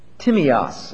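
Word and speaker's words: Timios (τίμιος): Timios is the way the word is pronounced. T-I-M-I-O-S, English letters.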